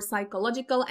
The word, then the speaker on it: psychological: They want to speak about zdrowie psychiczne and they want to look for psychological and they mispronounce it with P at the beginning.